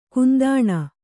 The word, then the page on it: ಕುಂದಣಿ: kundaṇi